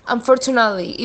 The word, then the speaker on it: unfortunately: The word unfortunately, the emphasis is on that second syllable. Unfortunately. Make sure you get the T in there. We don't want to say unfortunately. That's not really a word, so there's got to be a T in there. Unfortunately, and it's a really kind of a quick stop of a T.